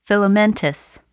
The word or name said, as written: filamentous